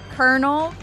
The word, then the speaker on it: colonel: I think I'm going to go with kernel, because it's actually spelled like colonel, but it's kernel, like a popcorn kernel.